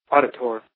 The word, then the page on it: auditor: American English